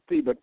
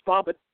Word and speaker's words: Thabit: And one of the things that the Imaginibus, Thabit Ibn, I want to pronounce it Thabit, but it's Thabit Ibn Qurra.